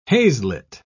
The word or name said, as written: haslet